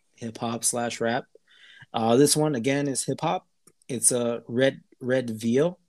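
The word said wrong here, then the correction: Redveil: Am I saying that right, Thomas? – Red. – Or Eric, am I saying that right? Red Veal. – Red Veil. – Veil. – Red Veil. – Like a veil.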